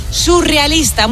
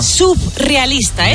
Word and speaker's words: surrealista: Y por otro lado, también decimos mal surrealista. Muchas veces la gente dice, y esto me pone muy nerviosa, subrealista.